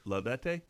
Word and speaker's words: Laudate: You know, something else, if you don't want to do the Magnificat, a simple way to start, what I did was, I think you pronounced the Laudat. – Laudate. Yeah, Laudate.